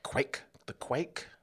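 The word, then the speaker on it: kveik: That's this Noel Boyle Norwegian style Quake.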